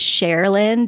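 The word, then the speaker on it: Cherilyn: My name is Sherilyn Decker and it's spelled C-H-E-R. So it sounds like share, like, will you share, you know, that blueberry muffin with me?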